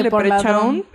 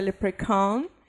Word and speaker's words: leprechaun: Yo siempre he dicho leprechaun, pero aparentemente se pronuncia leprechaun.